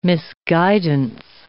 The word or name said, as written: misguidance